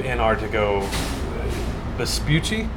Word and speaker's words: Antarctigo Vespucci: And then, so in like what I like about the stuff you guys do, like you do the podcast, do Antarctigo Vespucci. – Yeah. – Did I actually say it right? – Yeah, you did it.